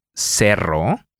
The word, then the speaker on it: cerro: The Spanish R can be pronounced in two different ways. To illustrate this, we are going to use two different words that vary in just one sound. Cero. – Zero. – Cerro. – A hill.